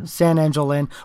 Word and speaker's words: San Angel Inn: I really like Sang Angel Inn. I can never say that right. – San Angel Inn.